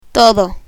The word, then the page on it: todo: Spanish